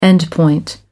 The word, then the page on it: endpoint: American English